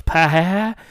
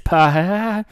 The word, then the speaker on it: Paget: I think it's just the first one and then vowel. So it's Paget. – I failed French in high school. I'm sorry. – Well, that's why, because you were pronouncing consonants. Just Paget.